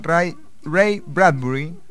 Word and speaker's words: Ray Bradbury: Ray Bradbury.